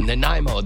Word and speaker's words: Nanaimo: Nanomi bars or Nanaimo, that's it.